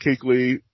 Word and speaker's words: Kuechly: That's how you say it, K-U-E? Oh, that's pronounced Kuechly.